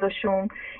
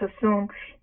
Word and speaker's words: Oshun: We call it Oshun. Here it's pronounced Osun.